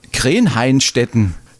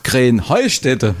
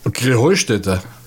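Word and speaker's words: Krähenheinstetten: Ja, ihr saget immer Krähenhainstetten. Und dabei heißt es doch Krähenheustetten. Oder wie? – Krähenheustetten. – Ja, genau. Und das die Betonung auf dem Heu.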